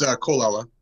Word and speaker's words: Colella: It's Colella.